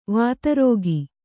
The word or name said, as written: ವಿದಾರಕ